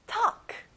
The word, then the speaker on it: talk: 자, L 발음은 안 나요? talk.